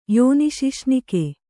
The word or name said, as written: ಯೋನಿಶಿಶ್ನಿಕೆ